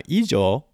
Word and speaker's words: 以上: Now, ijo, this is a long u sound, has a particular use, which is, two particular uses, and we should start with one and move on to the second.